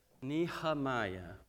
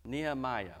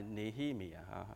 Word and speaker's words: Nehemiah: 佢話佢喺度笑到碌地，佢話唔係咁樣讀噶。咁我話咁我係照樣翻譯個尼希米嘅嘛 Nehemiah 啦，係嘛？佢話唔係啊，應該係 pronounce as Nehemiah。所以弟兄姊妹，我哋要又係。 Nehemiah， OK，唔係 Nehemiah 嚇。